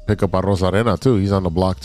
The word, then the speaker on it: Arozarena: Arozarena. – Oh, there you go. – Arozarena.